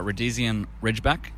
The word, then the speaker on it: Rhodesian Ridgeback: Rhodesian Ridgeback, Staffy Cross.